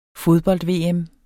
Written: fodbold-VM

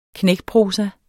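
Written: KNÆKPROSA

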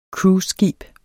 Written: cruiseskib

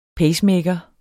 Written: pacemaker

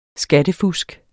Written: skattefusk

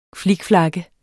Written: flikflak

